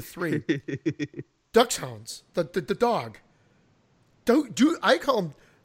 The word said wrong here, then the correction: Dachshund: I guess the correct pronunciation is a Dachshund.